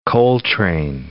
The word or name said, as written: Coltrane